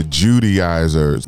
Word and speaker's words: Judaizers: Um, there was a group called the Judaizers. Hope I'm saying it correctly. – Yeah. That yeah.